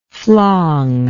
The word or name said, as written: flong